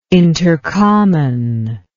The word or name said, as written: intercommon